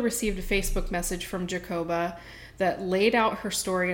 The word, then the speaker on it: Jacoba: Jacoba is such a different name. I've never heard it before. – It is a different name. It's like Jake. It's spelled Jacob A. – Yeah. – J-A-C-O-B-A. Jacoba.